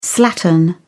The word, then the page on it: slattern: British English